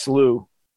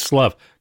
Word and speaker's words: slough: I think it's pronounced slew. – No, okay, it's not. All right, it's cool, actually pronounced slough.